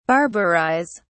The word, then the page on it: barbarise: British English